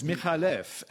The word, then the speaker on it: McAuliffe: McAuliffe is actually an Arabic name. And it's Michalef, and the C is just for spelling pronunciation.